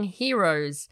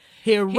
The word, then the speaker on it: Heroes: It is the song Heroes. And he kind of was... – No, sorry. That's not how you say it. – Sorry. – Yeah. – Heroes.